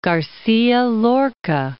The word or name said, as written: Garcia Lorca